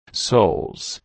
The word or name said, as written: soles